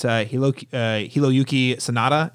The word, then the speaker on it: Hiroyuki Sanada: It's Hiroyuki Sanada.